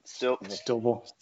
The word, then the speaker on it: Steelville: So somewhere near Potosi in the God knows what, Stillville, spelled like Steelville. – Yep. – Stillville.